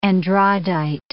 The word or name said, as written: andradite